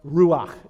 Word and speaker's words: ruach: In Hebrew, in the Old Testament, it's pronounced ruach is his word. Give that a try. Ruach. Yeah, you're clearing your throat just fine right there when you do that.